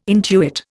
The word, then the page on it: intuit: Indian